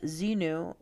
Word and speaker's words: Xenu: Okay, so Scientology teaches that Xenu, pronounced Zenu,